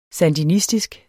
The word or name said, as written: sandinistisk